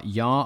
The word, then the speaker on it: Jaar: Nicholas J-A-A-R Yar, I think it's pronounced.